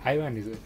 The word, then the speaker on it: Ibanez: entonces pues no se llama Ibáñez, güey, ni, se llama Ibanez, O sea, realmente eh, en japonés pues se pronuncia así como algo como Ibáñez, Ibáñez, una madre así, güey.